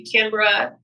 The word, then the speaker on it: Canberra: Oh my gosh, I'm going to be in, and if I'm saying I'm wrong, I'm sorry, I'm going to be in Canberra, Brisbane, Melbourne, and Sydney. – Pronounced perfectly. Yes.